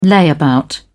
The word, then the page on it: layabout: British English